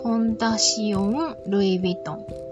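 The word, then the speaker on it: Fondation Louis Vuitton: フランスだと、フランス語だと、フォンダシオン・ルイ・ヴィトンと読むそうですが、えー、そういった活動の一環としてこのエスパス・ルイ・ヴィトンを運営していらっしゃいます。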